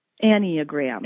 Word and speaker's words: Enneagram: Is it Enneagram? Is that how that's pronounced? – Enneagram. – Enneagram. Okay, with you.